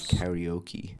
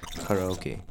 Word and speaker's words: karaoke: I should note here before going on that Americans say karaoke, which I guess works, but I grew up pronouncing this word karaoke, and that is how it's said in the original Japanese, so that's how I will say it.